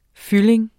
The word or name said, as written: fyldning